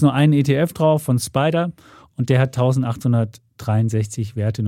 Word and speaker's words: SPDR: es gibt nur einen darauf von S SPDR, heißt Spider ausgesprochen. Der hat 1863 Werte.